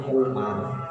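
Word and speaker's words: Umar (عمر): Nah kalau Ain Memro itu bacanya Umar ya kalau Ain Memro Wawu ini kan Ain Memro Wawu ya bacanya Amr ya di bidang integritas.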